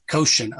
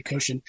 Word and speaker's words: Kotian: Like I wanted to ask you about uh, Beneath an Alien Sky, which is um, a, a, property and a story from Sid Kotian. I hope I'm saying that right. Uh, Koshin. Okay, Koshin. Hmm.